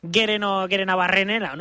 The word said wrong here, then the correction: Gerenabarrena: ¿no? – Bueno, pues casi. – Gerenabarrena. – Ahí está.